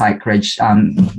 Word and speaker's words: PsychReg: Yes, and first I have to congratulate you, Richard, because not a lot of people would be able to pronounce PsychReg right straight away.